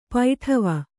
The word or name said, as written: ಪಡಾವು